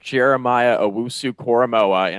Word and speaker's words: Jeremiah Owusu-Koramoah: I will say that Jeremiah Owusu-Koramoah, and I'm mostly saying that just because I want to get his name right.